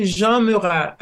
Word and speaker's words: Jean-Murat: Uh, it's Dr. Carolle Jean Murat. I still have my accent.